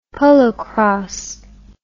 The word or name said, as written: polocrosse